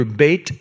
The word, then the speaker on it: Beit: Whenever you read the name of something in the Bible that starts with the word Beth, you would pronounce it in Hebrew, Beit.